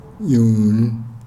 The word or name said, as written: nyuu